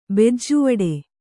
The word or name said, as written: ಬೆಜ್ಜುವಡೆ